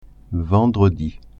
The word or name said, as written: vendredi